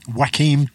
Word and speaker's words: Joaquim: Joaquim Dos Santos, who had worked previously on the Justice League Unlimited and Legend of Korra.